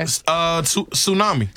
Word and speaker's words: tsunami: This has a silent letter in it. Okay. Tsunami.